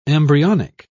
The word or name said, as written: embryonic